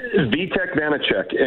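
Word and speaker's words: Vítek Vaněček: Vitek Vanacek, and he's a Slovakian kid, and he's been incredible.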